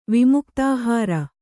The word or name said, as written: ವಿಮುಕ್ತಾಹಾರ